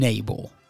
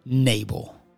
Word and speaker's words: Nabal: And when they got to this land, they found a very, very wealthy man named Nabal, also rhymes with cable. That's right, Nabal.